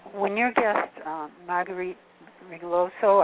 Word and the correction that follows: Rigoglioso: That's close enough. Rigoglioso. – Rigoglioso.